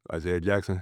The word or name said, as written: Isaiah Jackson